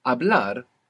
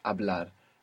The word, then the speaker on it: Hablar: Hablar is to speak in Spanish. Hablar. It's spelled H-A-B-L-A-R. What's happening with that H? – It's silent. – Totally silent. Hablar.